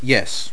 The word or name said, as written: yes